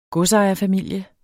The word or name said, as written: godsejerfamilie